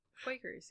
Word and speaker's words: Quakers: I might put be quackers. Really wrong. – What? – Oh no! You do spell it like quackers. – Quakers, yeah.